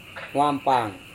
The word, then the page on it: huampang: Serewen